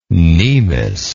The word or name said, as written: cnemial